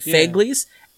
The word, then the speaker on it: Fegley's: Fegley's. – Yeah. – F-E-G-L-E-Y apostrophe S.